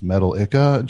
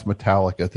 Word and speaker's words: Metallica: I would put me in that position, please, to say Metal Ica, to mispronounce Metallica to James Hetfield.